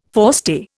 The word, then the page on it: forestay: Indian